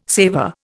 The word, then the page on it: saver: Indian